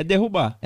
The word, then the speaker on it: derrubar: Aí, cara, pra poder tirar o buriti, era derribar. E a gente derrubava muito. Eu acho que essa palavra, tô falando certo? É derrubar. É derrubar, né? É. Eu sempre falo derribar, porque.